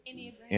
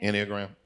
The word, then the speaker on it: Enneagram: Yeah, I think for me, so I'm studying this right now, particularly the Enneagram, if that's how you say it. – Enneagram. – Enneagram, okay.